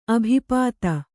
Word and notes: ಅಭಿಪಾತ: abhipāta

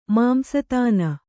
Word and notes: ಮಾಂಸತಾನ: Kannada

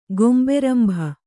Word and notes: ಗೊಂಬೆರಂಭ: Kannada

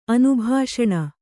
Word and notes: ಅನುಭಾಷಣ: kannada

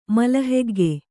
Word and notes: ಮಲಹೆಗ್ಗೆ: malahegge

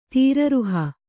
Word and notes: ತೀರರುಹ: tīraruha

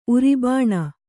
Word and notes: ಉರಿಬಾಣ: uribāṇa

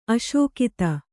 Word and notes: ಅಶಕ್ತ: aśakta

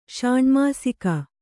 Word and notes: ಷಾಣ್ಮಾಸಿಕ: ṣāṇmāsika

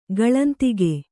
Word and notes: ಗಳಂತಿಕೆ: gaḷantike

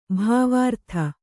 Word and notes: ಭಾವಾರ್ಥ: kannada

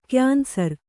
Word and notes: ಕ್ಯಾನ್ಸರ್: kyansar

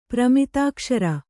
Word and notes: ಪ್ರಮಿತಾಕ್ಷರ: pramitākṣara

